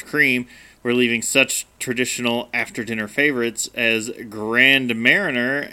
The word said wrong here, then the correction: Grand Marnier: and... – Grand Marnier. – Sorry.